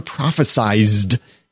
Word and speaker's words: prophesied: You were prophesied to come. It's prophesied, in case you're wondering why I emphasize the Z. Maybe that's how they say it in Camelot.